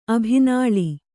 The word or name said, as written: ಅಭಿನಾಳಿ